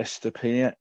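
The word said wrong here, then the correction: Estupiñán: yeah, Estepiniana. – thank you.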